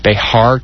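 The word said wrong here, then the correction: Bexar: County? – Bear County.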